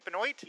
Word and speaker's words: Benoist: And wouldn't it be amazing to have a Supergirl treasury with like a photo cover of Melissa Benoist? Is that how you say it? Benoit? – Yeah.